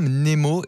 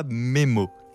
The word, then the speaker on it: mnémo: Et c'est bien mnémo et non pas mémo. – Oui. Ah, il faut prononcer le N.